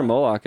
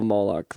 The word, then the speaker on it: Moloch: No, that's a symbol of Moloch, though. – Okay. – Or Molech. It's M-O-L-O-C-H, but I think it's Molech. That's how I hear it pronounced for some reason.